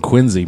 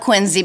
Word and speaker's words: Quincy: I love Quincy because I love the soft S. – It's a we pronounce it bad. We pronounce it wrong. We say we pronounce it with a Z.